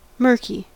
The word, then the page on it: murky: American English